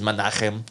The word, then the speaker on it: Menachem: Menahem. – Yeah, Menahem. Or Globus. – Menachem. Yeah, Menachem. Menachem. That was like, yeah. It, if you're going to really pronounce it, it's Menachem.